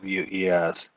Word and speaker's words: Meiwes: From prehistoric through the Renaissance and right up to the 21st century, with the Meows case. I don't know if I pronounced that right. It's Meiwes.